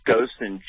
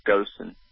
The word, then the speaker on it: Goson: My name is pronounced Gossin, G-O-S-O-N is how it's pronounced, Gossin.